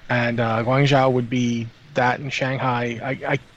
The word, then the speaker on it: Guangzhou: 6606 in the chat channel says pronounce it Guangzhou, apparently, because they go to hospital there. So that's good. Guangzhou.